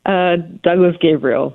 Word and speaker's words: Douglas-Gabriel: Douglas-Gabriel.